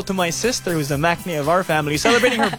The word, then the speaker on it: maknae: Yeah, so I'm the maknae of Y101. – Yes, you are. – That's nice. I'm proud to have that title. I'm maknae here.